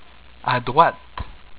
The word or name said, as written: A droite